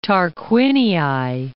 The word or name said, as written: Tarquinii